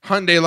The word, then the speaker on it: Hyundai: Where the car people are like, it's Hyundai like Sunday.